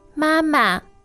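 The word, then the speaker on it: Mama (妈妈): I want to look at the word for mother. – Mama. Mama. – Now, that's first tone, right? – Yeah.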